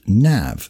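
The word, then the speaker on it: naomh: It is interesting to note that the Irish word for saint is Nam, pronounced Nav, and that Yarbanel is said to be a son of Nemed, also spelled Nimar.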